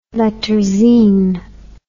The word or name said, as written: letterzine